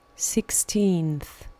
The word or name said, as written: sixteenth